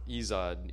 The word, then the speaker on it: Ezod: Like, yeah, it's Ezod.